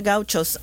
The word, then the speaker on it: gauchos: I've read about, what are they, gauchos? Am I saying that correctly? – The gauchos.